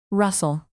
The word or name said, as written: russell